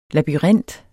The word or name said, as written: labyrint